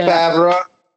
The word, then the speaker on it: Favreau: Favreau? How do you pronounce the last name? – Oh, yeah, you said it right. – Yeah. Favreau.